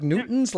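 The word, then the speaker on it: Dewtons: Like Newtons? Like Olivia Newton-John? What? – Yes, yes. Pronounce it like Fig Newton, like Newton, yes. – Okay. And is that how you spell it, too? – Yeah, D-E-W-T-O-N.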